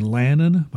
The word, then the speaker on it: Lannan: The best way to find it is just to search under Podcasts by Larry Lannan. My last name is spelled L-A-N-N-A-N.